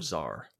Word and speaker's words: czar: It's his mom tells us that she saw a star fall into the barrel volcano and the Caesar dragon is guarding it. Or Czar. – Yeah. – Another one of those childhood pronunciations.